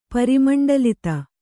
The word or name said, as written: ಪರಿಮಂಡಲಿತ